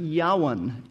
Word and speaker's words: Javan: And the last son of Japheth that we want to look at is Yawan. It looks like Javan, but it should be pronounced Yawan. Yawan.